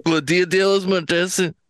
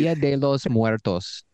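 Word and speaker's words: Día de los Muertos: That is La Dia de los Muertos and hey, come on, hold on. Let's pronounce that correctly. Please. Dia de los Muertos.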